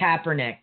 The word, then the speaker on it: Kaepernick: Kaepernick, yeah, which I love him.